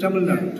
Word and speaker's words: Tamil Nadu: And we're in Tamil Nadu? Yeah. I'm, i say that right? Yeah, yeah. Go ahead and say it. Pardon me? Tamil Nadu? Tamil Nadu.